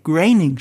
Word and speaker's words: Groening: Matt Groening hat immer gesagt, äh, nee, Graining spricht man es aus, habe ich gelernt. Man, man sagt Matt okay. Groening. Mhm. – Es wird komplett anders geschrieben, aber es heißt Matt Groening.